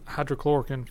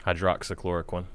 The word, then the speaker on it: hydroxychloroquine: And he's been taking hydrochloroquine forever. – Hydroxychloroquine.